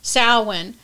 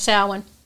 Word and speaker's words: Samhain: So it is, this originally started in Celtic Ireland, and I'll talk a little bit about that, but it's pronounced Samhain. Some people call Samhain, but the correct pronunciation is Samhain.